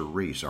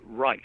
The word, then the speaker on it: Reis: Do you say his name Rice or Reese? R E I S. – Rice. – It's Rice, Irving Rice.